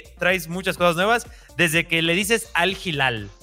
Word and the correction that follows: Al Hilal: Así se pronuncia, ah, ¿no? – Al Hilal.